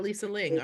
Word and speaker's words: Lisa Ling: And what about Lisa Ling? Aren't you working? Did I say her name correctly? – You said her name correctly.